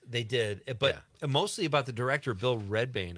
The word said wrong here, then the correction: Rebane: I think. Okay. That's how you say it. Rebane.